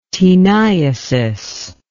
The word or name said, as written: teniasis